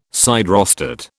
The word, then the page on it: siderostat: British English